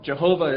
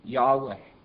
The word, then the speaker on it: Yahweh: Now, Jehovah is simply the Latin or the German version of Yahweh. But most Hebrew scholars, I think, would agree that the proper pronunciation was something closer to Yahweh.